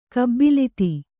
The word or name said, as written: ಕಬಲಿತ